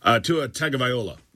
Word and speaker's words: Tua Tagovailoa: The two plays involving Tua Tagovailoa. Look, I learned how to say his name. Pretty proud of me, aren't you? Tua Tagovailoa, all right, takes two vicious hits about 5 days apart.